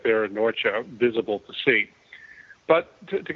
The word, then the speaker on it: Norcia: Well, Nathan, you pronounced it correctly. It is Nursia, by the way. Although today the same town is still there in the Italian mountains, but they call it Norcia, N-O-R-C-I-A.